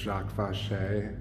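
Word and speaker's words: Jacques Vaché: Have you ever heard of, there was this surrealist figure, I think his name is pronounced Jacques Fauché.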